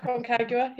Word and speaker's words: Aconcagua: So the seven peaks, obviously there's Everest, the South American one, I can never pronounce this properly, Aconcagua? – Yeah. – Something along those lines? – Aconcagua, yeah.